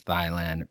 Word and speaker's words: Thailand: Here's the deal with the Thailand versus Thailand pronunciation. It's pronounced Thailand. That's the way that Thai people pronounce it. T-H-A-I is pronounced Thai. They are Thai. They're not Thai.